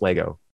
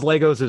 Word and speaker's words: Lego: It is pronounced Lego. Anyone who says Legos is wrong.